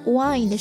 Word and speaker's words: wind: 好了，一定要记得 wind 是风， wind 是蜿蜒，写法一样，读音不一样，意思也不一样。